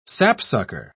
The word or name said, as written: sapsucker